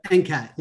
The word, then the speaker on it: NCAT: NCAT, is that what you call it, NCAT? – NCAT, yep, NCAT.